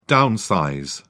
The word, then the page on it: downsize: British English